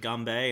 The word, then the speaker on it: ganbei: Yeah, I mean, the pronunciation changes a little. It's like ganbei instead of kanpai, but essentially mm-hmm.